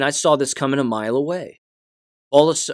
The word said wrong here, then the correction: Bolsonaro: uh, uh, Bolsonaro, my apologies for getting his name wrong, Bolsonaro was pushed into a runoff.